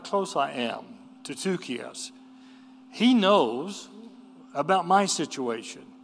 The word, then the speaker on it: Tychicus: An upsilon is what we usually have as a Y, the Greek letter. But it's always pronounced like U or U. So that T-U is Tychicus.